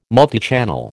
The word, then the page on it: multichannel: American English